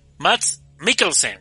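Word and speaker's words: Mads Mikkelsen: Protagonizada por Mats Tickelsen, carnal, ¿lo pronuncié bien? – Es este, a ver, ahorita te digo. – No, Matt Mikkelsen, perdón.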